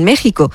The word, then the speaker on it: México: La verdad es que iba a hablar de Ayuso, en México, que le han dado una lección. Ya le han enseñado que México se escribe con X y se pronuncia con J.